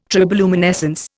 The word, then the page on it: triboluminescence: Indian